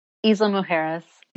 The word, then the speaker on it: Isla Mujeres: And so today we're talking about the Isle of Mujeres. Am I saying that correctly? – Isla Mujeres. – Isla Mujeres.